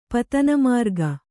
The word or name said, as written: ಪತನಮಾರ್ಗ